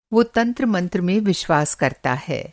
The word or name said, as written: तंत्र-मंत्र